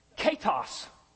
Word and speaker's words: Ketos: It's, and I want to make sure I pronounce it right. Ketos. K-E-T-O-S is the transliteration.